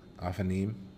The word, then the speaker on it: Ophanim: Ophanim. – Eam. That eam, that im at the end, eam suffix, that's the plural. That's the plural suffix in Hebrew.